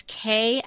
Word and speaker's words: Kei: And it's Kay and Molly. But Kay is spelled K-E-I.